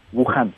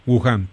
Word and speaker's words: Wuhan: Son 3.554 eh, en la provincia de Hubei, incluido este Guam. ¿Cómo se pronuncia? – Wuhan. Wuhan. Wuhan, perdón. Wuhan, de los cuales 671 son graves y 228 críticos.